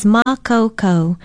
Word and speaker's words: makaukau: Aloha, I'm Leilani Poliahu of Hawaii Public Radio, and our Hawaiian word for today is makaukau. Makaukau is spelled M-A-K-A-U-K-A-U with a kahako or stress on the first vowel, and it means able, competent, capable, skilled, expert, prepared.